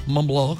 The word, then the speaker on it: Montblanc: ¿Cuál? Montblanc. Que así se pronuncia.